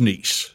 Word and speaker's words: Kniess: Now, my last name's pronounced niece.